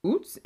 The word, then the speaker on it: Ute: The Utes and the Navajo. It's spelled U-T-E-S or U-T-E, the Ute tribe.